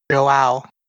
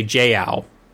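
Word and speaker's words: João: The pronunciation is Joao. – I almost did that, but then I thought that was wrong. – I keep wanting to say Jiao instead of Joao.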